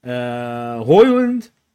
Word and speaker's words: Højlund: Højlund started, then got subbed off. You know, Gilmore playing. – Did you just pronounce that correctly? I think you just pronounced his name correctly. – Højlund.